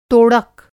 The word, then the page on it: तोड़क: Hindi